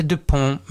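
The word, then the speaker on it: Dupond: Den ena heter ju Dupont och den andra heter Dupont med T, men det uttalas ju precis likadant.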